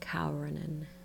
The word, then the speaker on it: Karanen: But I think in Finland it's pronounced Kaurinen. – Oh. – Kaurinen. Because they roll their R's.